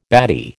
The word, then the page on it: baddy: American English